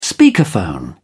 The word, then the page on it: speakerphone: British English